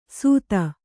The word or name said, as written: ಸುಧ